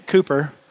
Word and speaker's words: Cowper: To close, I want to tell you about this guy named William Cooper. His name is spelled like Cowper, if you want to look him up.